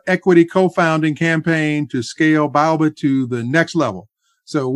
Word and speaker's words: Baobab: So, first, let me correct the pronunciation, which is Baobab.